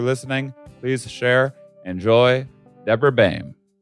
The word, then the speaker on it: Baim: Throughout the episode, I pronounced her name incorrectly because I went to high school with someone who spelt their name the exact same way and they pronounced it Beam. I thought that was a universal thing.